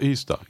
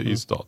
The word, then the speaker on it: Ystad: Men då måste jag rätta dig, som har bott där, att det uttalas inte. – Nej. – Ystad. – Alltså Ystad, inte Ystad. – Ystad säger man inte. – Nej, Ystad. – Det är som Lidköping.